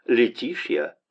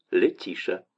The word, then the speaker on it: Летиция: «Тиша» — уменьшительно ласкательная форма женского имени Летиция, которая по-русски традиционно передается как Летиция, но по-английски произносится Летишья или Летиша.